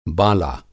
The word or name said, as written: बाला